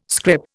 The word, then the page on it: scrip: Indian